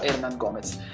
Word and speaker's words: Hernán Gómez: alcuni podcast lo chiamano Hernán Gómez, mi stava per scappare sta pronuncia, non so come mai. Ho chiesto a un mio amico spagnolo, mi ha detto che si pronuncia Hernán Gómez.